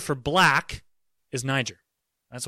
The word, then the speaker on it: Niger: Simeon is called, his nickname is a word that you have to be very, very, very careful around, and that is Niger. That's a long I and a soft G.